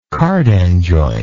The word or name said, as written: cardan joint